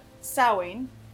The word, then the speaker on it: Samhain: One that most people know is that Samhain, mm-hmm. is how it's pronounced, which is a Celtic festival of mm-hmm.